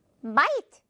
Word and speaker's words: might: GH는 빼버리고 I 발음 납니다. 중간에 있는 I는요. 그래서 might 이렇게 발음하면 되죠. Might. 전혀 어렵지 않죠. GH 발음도 안 나면서 왜 여기에 껴있는 걸까요? 네, 없애버리면 됩니다. 발음상.